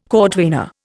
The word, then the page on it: cordwainer: Indian